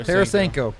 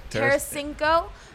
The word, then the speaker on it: Tarasenko: Tarasenko. – Tarasenko.